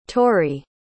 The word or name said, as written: tauri